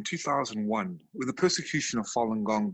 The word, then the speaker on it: Falun Gong: So when Falun Gong was introduced into the general public of China, and it's pronounced Falun Gong, it spread like wildfire, Paul.